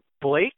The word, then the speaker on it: Blace: Blake, I believe it's pronounced. It's spelled B-L-A-C-E.